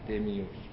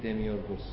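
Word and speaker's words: دمیورگوس (δημιουργός): نباید دمیورگ بگید، دمیورگ تلفظ فرانسویش، نباید بگید دمیورگ، باید بگید دمیورگوس.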